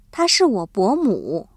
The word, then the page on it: 伯母: bómǔ